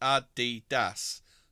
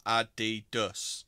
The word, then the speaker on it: Adidas: Adidas should be pronounced Adidas. Not Adidas, as most of us say.